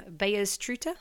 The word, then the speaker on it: Beyers Truter: Oh, God, I bet you I'm going to pronounce this wrong. The winemaker, Beyers Truter. – So Beyers is very, very, very accurately pronounced.